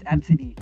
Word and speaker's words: Abcde: And she's like, "Oh, it's Absidy." It's weird. – Sounds cool. Absidy. But when you spell it, it's like A B C D E.